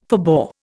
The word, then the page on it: forbore: Indian